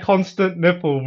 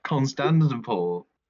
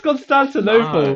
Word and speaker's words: Constantinople: Why are you saying it like constant nipple, bro? – Constantinople. – It's Constantinople.